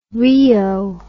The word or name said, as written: rheo